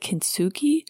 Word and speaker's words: kintsugi: So though, those of you who have heard of the arts of, I think you pronounce it kintsugi,